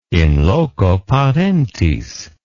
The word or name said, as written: in loco parentis